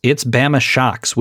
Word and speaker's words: Bama Shocks: And he's like, it's not like Obama, it's like Alabama.